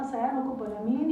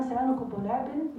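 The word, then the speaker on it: cyanocobalamin: سيانوكوبالامين زي ما بتحبوا تنطقوا، هي اما سيانوكوبالامين يا اما سيانوكوبولابين، يعني هي هياها فيتامين بي 12.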